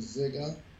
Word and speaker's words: Ziga: Ziga, Z-I-G-A. That's how we, years ago, when my relatives, they used to pronounce it Ziga.